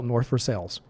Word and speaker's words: Versailles: In Pittsburgh, we have a town that is spelled the same way as Versailles in France. It's North, and it's spelled Versailles. And we call it North Versailles. And everybody calls it that.